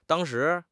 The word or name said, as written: dang1 shi2